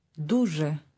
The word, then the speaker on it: дуже: Дуже. Дуже. – Say very. – Дуже. – Now here's very pleasant, which also means pleased to meet you. Repeat. – Дуже приємно.